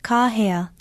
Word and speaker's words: kahea: That's kahea, meaning to call out. Put stress on the first vowel and pronounce it kahea.